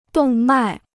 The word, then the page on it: 动脉: dòng mài